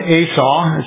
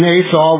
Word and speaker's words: Asoh: The date was November 22nd, 1968, and Captain Asaw, it's spelled A-S-O-H, he was a pilot for Japan Airlines.